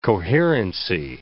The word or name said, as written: coherency